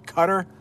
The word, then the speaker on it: Qatar: Angelo is here from Qatar. That's how we pronounce it in English. Qatar. Sometimes you'll hear people say Qatar, but I think it's Qatar. I think it's Qatar.